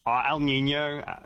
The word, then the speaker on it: El Niño: El Niño.